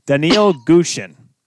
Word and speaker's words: Daniil Gushin: And we've talked about him before, Rachel, and I'm going to say it wrong. Daniil Gushin. – You nailed it. Yeah. – Yes. – That's right.